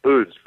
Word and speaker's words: Uz: how would you pronounce it? Uz, Uz, Uts? – Uz, that's right.